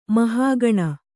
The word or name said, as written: ಮಹಾಘನ